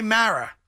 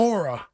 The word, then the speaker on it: Mara: Like, why can't you say Mara? That's his name. – That's an easy name to pronounce. – It's not Mora.